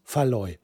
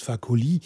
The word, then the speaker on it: Fakoly: Und nicht Faloy. Faloy ist völlig falsch. Was ich immer gesagt habe, warum auch immer, habe ich ihn immer Faloy genannt. Aber er heißt Fakoly.